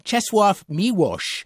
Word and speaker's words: Czesław Miłosz: Czesław Miłosz.